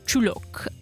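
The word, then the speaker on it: Csülök: Jego imię wymawia się Czulok, a po węgiersku znaczy właśnie golonka.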